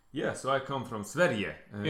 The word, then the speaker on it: Sverige: Sverige.